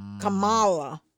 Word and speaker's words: Kamala: And since you said I pronounced her name wrong, Kamala.